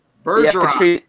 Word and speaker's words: Bergeron: Bergeron.